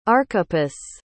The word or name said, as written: archippus